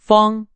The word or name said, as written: 方